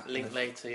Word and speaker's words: Linklater: how do you pronounce his surname? – It's either Linklater or Linklater. – Linklater, yeah. Surely, 12 years of his life spent on the film.